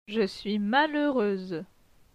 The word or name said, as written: Je suis malheureuse